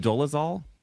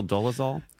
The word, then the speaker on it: Dolezal: Yeah, and is it pronounced Dolezal? Yeah. Rachel Dolezal? – Yes, I do pronounce that way.